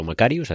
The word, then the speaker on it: Macharius: De eso vamos a hablar hoy, un Macarius que por cierto se escribe Macharius y por supuesto pues habrá quien lo diga de una forma o de otra. A mí siempre me ha salido llamarlo Macarius,